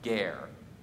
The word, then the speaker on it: ger: Uh, the Hebrew word that most closely captures the English concept of a stranger is a word that's pronounced ger ger.